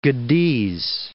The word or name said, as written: Gediz